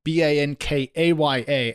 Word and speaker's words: Bankaya: Yeah, well, according to CEO and co founder Mauricio Cordero, it's pronounced more Bankaya, but he breaks it down a little bit more by saying Banca Ya means bank now.